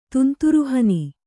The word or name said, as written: ತುಂತುರುಹನಿ